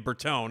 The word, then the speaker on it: Bertone: It's actually Bertone. – Oh, Bertone, of course. Of course it is. – And, yes. – And if it matters, I've been mispronouncing that for 40 years. I've been saying Bertone.